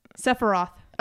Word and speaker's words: Sephiroth: How do you pronounce that? – Sephiroth. – Oh, okay.